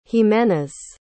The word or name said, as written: jimenez